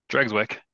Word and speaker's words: Dragswiek: Dragswiek, yeah.